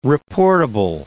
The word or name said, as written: reportable